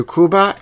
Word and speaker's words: Cuba: Well, Cuba is the proper pronunciation of the country, not Cuba. – Not Cuba. – It's Cuba.